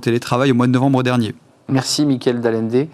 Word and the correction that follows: Dalande: je l'ai bien dit. Oh, c'était Dalande. – Dalande, euh, mais, vous voyez, mais vous reviendrez, Michael Dalande.